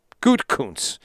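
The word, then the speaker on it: Gutekunst: Gutkunst, like Gouda, Good, and then Kunst. Gutkunst.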